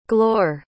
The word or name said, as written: glore